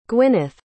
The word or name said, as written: gwynith